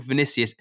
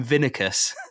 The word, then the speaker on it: Vinicius: How have you interpreted Leno is Lino, and he called Vinicius, he calls him Vinicus.